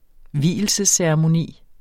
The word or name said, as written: vielsesceremoni